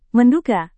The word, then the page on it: menduga: Indonesian